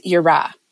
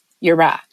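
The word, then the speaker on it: yirah (יראה): One is charada, and it's spelled C-H-A-R-A-D-A-H, charada. And the other is yirah, yirah, and it's spelled Y-I-R-A-H.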